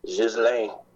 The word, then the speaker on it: Ghislaine: So that's from my research, that's the southern French way to pronounce it, and Ghislaine with a hard G and without the S sound, with the silent S, is the northern way.